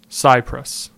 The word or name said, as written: Cypress